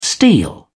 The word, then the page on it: steel: British English